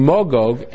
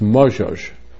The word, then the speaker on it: Magog: Rav Aaron Marcus, however, in his Kesses HaSefer, points out the connection between Magog and the Mongols. See, we pronounce both as a hard G, Magog. But really, according to Yemenite pronunciation, it's Mozhoj. It's a soft G.